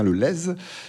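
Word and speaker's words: Castelnau-le-Lez: Vous venez du département de l'Hérault, de Castelnau-le-Lèze. On prononce bien le Z, hein, je crois. – Tout à fait. – On dit bien le Lèze.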